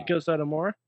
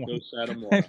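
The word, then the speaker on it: Meiko Satomura: Mako Satomura. – Yes, thank you. – Mako Satomura.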